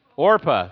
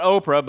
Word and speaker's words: Orpah: So Ruth and Orpah. Yeah, however you say her name. Not Oprah, but Orpah.